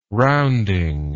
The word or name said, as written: rounding